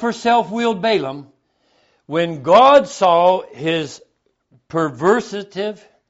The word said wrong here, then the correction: perversity: perversity. Am I saying it right? – Perversity. – Thank you.